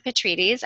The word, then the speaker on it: Petrides: And today we have Lisa Petrides, and I am am I pronouncing that correctly? – Absolutely, yes, okay.